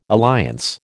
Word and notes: alliance: American English

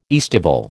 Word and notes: aestival: American English

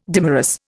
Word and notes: dimerous: Indian

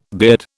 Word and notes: beard: British English

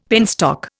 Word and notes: penstock: Indian

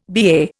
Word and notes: BA: Indian

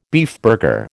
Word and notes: beefburger: American English

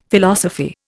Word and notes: Philosophy: Indian

